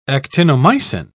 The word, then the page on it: actinomycin: British English